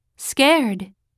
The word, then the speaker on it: scared: Scared. Scared. – 最初の S は日本語のすとは違うよ。息だけですと発音するよ。